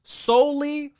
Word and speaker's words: Sowley: If you happen to shout out my store, which you don't have to, it's pronounced solely for pets. And now it's spelled S-O-W-L-E-Y. Soli was my great grandfather's name.